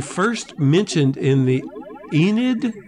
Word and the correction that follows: Aeneid: I think is the way you pronounce it, A-E-N-E-I-D, The Aeneid. – Aeneid.